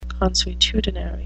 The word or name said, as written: consuetudinary